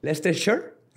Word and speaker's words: Leicestershire: Y this is Leicestershire. – Leicestershire. – Leicestershire. – No dicen Shire.